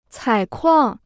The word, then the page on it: 采矿: cǎi kuàng